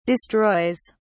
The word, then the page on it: destroys: British English